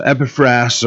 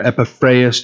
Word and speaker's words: Epaphras: So Epaphras or Epaphras, depending on who you're, whose pronunciation you're going to use.